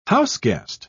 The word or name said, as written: houseguest